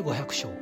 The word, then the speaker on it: 五百生: 500 回生きると書いて 500 章と読みます